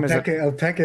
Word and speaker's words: Opeka: It's a documentary, and it's called Opeka. Is that pronounced correctly? – Opeka is exactly right.